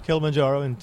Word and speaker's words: Kilimanjaro: There's so many different there's warm, there's much warmer, there's cold up on, you know, is it Kilimanjaro? – Yes, Kilimanjaro.